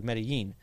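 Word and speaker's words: Medellín: If you pronounce it like Spanish, you know, with the double L's, it'd be like Medellin. But colloquially, in there, they pronounce double L's like a hard J.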